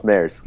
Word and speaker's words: Mares: Close, Maris.